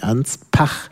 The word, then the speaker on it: Anspach: Das schreibt man mit P, Anspach, nicht wie Ansbach.